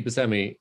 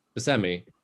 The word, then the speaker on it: Buscemi: I mean, this is like, you know, it's because, you know, Steve Buscemi pronounces it Buscemi, but it doesn't stop people from pronouncing it Buscemi.